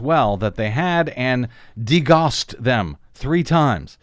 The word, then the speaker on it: degaussed: Degaussed? How do you say that? – Degaussed. Yeah, we would say degaussed.